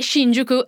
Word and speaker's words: Shinjuku: E Shinjuku è un quartiere di Tokyo, sto cercando di pronunciarlo da giapponese, tra l'altro apprezzalo. – Mi sembra che ti sia venuto molto bene.